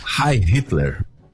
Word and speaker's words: Heil Hitler: Heil Hitler.